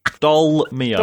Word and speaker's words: Dolmio: Why do you keep saying Dormio? Dormio. Yeah. – The Dormio, what am I saying? Is that wrong? Dormio? – There's an L in it.